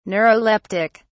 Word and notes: neuroleptic: British English

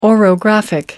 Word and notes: orographic: American English

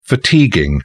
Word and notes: fatiguing: British English

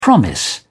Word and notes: promise: British English

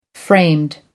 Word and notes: framed: American English

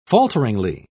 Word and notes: falteringly: British English

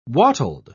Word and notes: wattled: British English

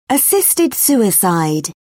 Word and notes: assisted suicide: British English

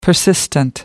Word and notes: persistent: American English

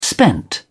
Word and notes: spent: British English

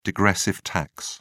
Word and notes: degressive tax: British English

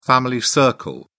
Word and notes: family circle: British English

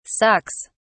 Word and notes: sucks: British English

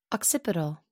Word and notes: occipital: American English

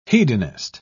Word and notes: hedonist: British English

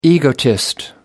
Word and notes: egotist: American English

